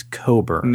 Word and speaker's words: Cockburn: It's spelled like Leechy, like L-E-C-H-E or whatever. She is claiming, of course, before she ran – her last name is not Cockburn, it's Coburn.